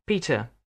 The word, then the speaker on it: Peter: Yes, it's W-I-L-L-I-A-M-S. – Thank you. What's your first name? – Peter. That's spelled P-E-T-E-R.